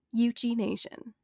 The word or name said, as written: Yuchi (Euchee)